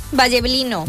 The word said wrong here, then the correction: Villablino: En Villablino. – Ah, eso sí. – No era el Valle, era el Ville.